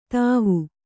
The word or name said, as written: ಥಾವು